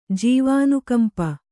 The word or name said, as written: ಜೀವಾನುಕಂಪಿ